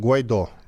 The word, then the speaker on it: Гуайдо: Я правильно произнес? – Вроде слово? да. Наш с тобой коллега Евгений Попов на Фейсбуке огромными буквами написал, как правильно произносить фамилию. – Ну хорошо, будем Гуайдо. знать. – Там Гуайдо. Гуайдо, да.